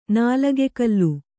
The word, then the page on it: ನಿಲುಕುಗಾಲ್: nilukugāl